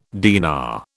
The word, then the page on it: dinar: British English